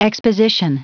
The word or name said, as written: exposition